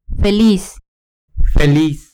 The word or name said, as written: feliz